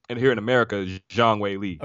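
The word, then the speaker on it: Zhang Weili: So it's Zhang Wei Li for now and forever.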